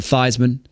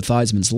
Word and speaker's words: Theismann: who was once known as Joe Theismann before they changed his name from Theismann to Theismann to make it rhyme with Heisman and make that candidacy.